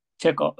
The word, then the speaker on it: Chekhov: Chekhov.